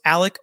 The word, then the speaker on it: Alex: So a lot of people are saying, is it Alex? Is it Alec? The defense already said different ways to pronounce Murdaugh and Murdaugh. I've been told by sources in the know it's Alec Murdaugh is the appropriate pronunciation, so I'm doing my best to stick with that.